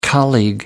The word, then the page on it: colleague: American English